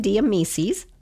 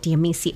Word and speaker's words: Amicis: Ryan Amicis. Did I say it right? – It's very close. Amicis. – Oh, the S is silent.